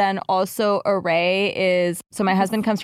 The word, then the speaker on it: Arrae: Array. – Array.